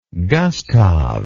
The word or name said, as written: gustave